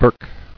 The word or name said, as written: Burke